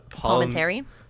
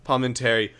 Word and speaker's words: Palminteri: Okay, one thing that drove me crazy going back through this movie was Chaz, how do you say his name? Chaz Palminteri. – Palminteri.